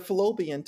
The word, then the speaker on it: Fallopian: Philippian? – No. Philippian. Her fallopian tubes. Did I say that right? Fallopian? – Yeah.